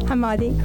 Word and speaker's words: Hammadi: Hammadi.